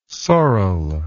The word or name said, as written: sorrel